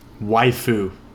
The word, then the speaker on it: Waifu: Totally my Wagyu. Did I say that right? – Waifu. – Waifu. Oh, whatever. Yeah, Wagyu's like... – It's like wife. It's like wife with an ooh on the end.